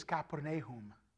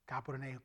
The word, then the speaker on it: Capernaum: Our guide was he was a Jew, but he was saved, and so the pronunciation is Capernaum. Capernaum. Kappa is a city. Nahum, the city of Nahum.